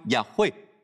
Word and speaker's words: Yahweh: 但我认为神的儿女可以说 Yahweh，我相信就是这样发音 Yahweh，因为 Yahweh 是我们的天父，我们爱他，我们敬爱他。